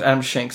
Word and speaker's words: Schenk: Nope, it's actually Adam uh, Sheckney. How do you say that? Is that correct? Adam. Oh, Adam Schenk. Adam, Adam Schenk.